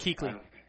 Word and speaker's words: Kuechly: What happened was Conkley, what's his name? I'm sorry. – Kuechly. It's pronounced Kuechly, yeah.